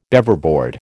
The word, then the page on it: Beaverboard: American English